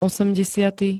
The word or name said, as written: osemdesiaty